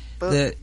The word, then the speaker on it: Buttigieg: What's happened is that Buttigieg, is that how we say his name? – Buttigieg. – Buttigieg. – I found out how to say it.